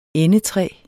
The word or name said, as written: endetræ